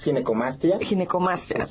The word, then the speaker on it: ginecomastia: Sí, exacto, es ginecomastia, ok.